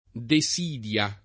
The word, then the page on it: desidia: de S& d L a